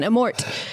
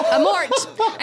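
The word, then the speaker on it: Amorth: I don't know whether you're supposed to pronounce the T in French or not. – It's Italian. Amort. – Amort. And so it's Italian and you say the T.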